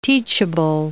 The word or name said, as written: teachable